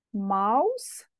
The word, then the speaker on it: mouse: Atenție la pronunție, se scrie ca în limba engleză mouse, se pronunță ca în română mouse.